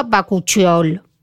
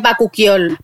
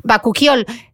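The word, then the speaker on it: bakuchiol: ¿Lo he dicho mal o lo he dicho bien? Bacuchiol. – Ahí tenemos una montada, dice la doctora María Jesús Lucero, que es Bacuquiol. Bacuquiol. Ah, sí, porque sí, Bacuquiol.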